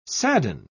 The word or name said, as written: sadden